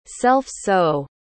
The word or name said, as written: self-sow